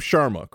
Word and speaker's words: Sharma: Okay, speaking of, so now we got a little bit of Priya, and your last name, Sharma, correct? – Yeah.